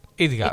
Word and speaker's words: IDGAF: IDGAF. – Eso. – IDGAF. Es como una sigla, claro.